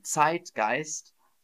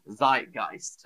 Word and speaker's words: Zeitgeist: So it would actually be pronounced in German, Zeitgeist. But in English, we make it sound nice in English. So we go, Zeitgeist. The Zeitgeist.